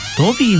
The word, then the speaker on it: Tobey: ¿Tommy o Toby? – Toby, mira. – Se escribe Tobey. Tobey Maguire.